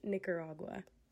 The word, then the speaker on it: Nicaragua: It is pronounced Nicaragua.